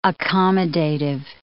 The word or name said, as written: accommodative